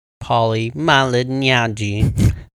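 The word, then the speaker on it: Paulie Malignaggi: So, suck it, Pauly Malignaggi.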